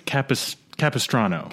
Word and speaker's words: Capistrano: I think it's Capistrano. – Capistrano. – The Curse of Capistrano.